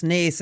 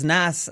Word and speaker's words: Naas: A little birdie told me that you pronounce Naas as Nass, Lee, here on one of the shows before, which is probably the worst pronunciation I've ever heard, which probably outdoes any terrible pronunciation I've ever done.